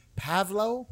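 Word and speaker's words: Pavlou: I'm going to butcher this, but George Pavlo, I believe is how you pronounce it.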